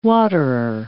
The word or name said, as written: waterer